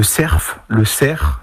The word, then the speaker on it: cerf: Alors le cerf, donc le F se prononce ici, je ne sais pas pourquoi, est vraiment dans toutes les déclinaisons, donc saucisse, barbecue, en salade.